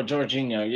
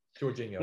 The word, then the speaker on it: Jorginho: I, might be not pronouncing his name wrong, but you, Jorgen Ho uh, defender, uh, midfielder. I could I could be pronouncing his name wrong. – Yes. Oh, Jorginho. Yeah. – Jorginho.